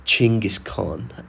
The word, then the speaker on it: Chinggis Khan: And then, top of the stairs, back of the frame, but center frame, it's a massive statue of Genghis Khan, which, as we know, is actually pronounced Chinggis Khan.